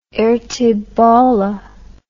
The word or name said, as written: ertebolle